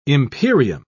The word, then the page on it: imperium: British English